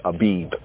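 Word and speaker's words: Abib (אביב): Or some, the Khazarim pronounce it as Aviv. But more properly, it's Abib, like Adis Abebe. It's from Abebe, not Aveve, right?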